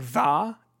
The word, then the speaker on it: Tha: This is the puzzle game coming from developer THA, or is it just pronounced like Tha Limited? – Tha.